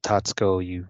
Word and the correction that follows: Tatsuki: you kind of go, or Tatsuki, you kind of go over the U like in Asuka.